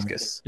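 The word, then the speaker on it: Dacascos: So it was not going to be Mark, pronounce his name for me, Dacascus.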